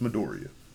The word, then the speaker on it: Midoriya: No, first off, don't be disrespectful. It's Midoriya.